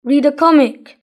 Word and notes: read a comic: British English

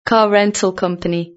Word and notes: car rental company: British English

